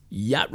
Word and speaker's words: yacht: Yacht Rock. That's Y-A-H-C-T. Is that Y? Did I spell that right? Y-A-C-H-T. Oh, duh. Yacht Rock. Doesn't even, it seems like it should be Y-O-T. Y-O-T Rock.